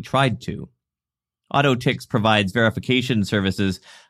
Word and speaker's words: AU10TIX: AutoTix, which is spelled AU10TIX, confirmed the breach happened about 18 months ago, and that the compromised credentials were revoked after what they called a thorough investigation.